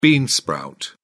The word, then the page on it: bean sprout: British English